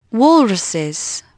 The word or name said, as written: walruses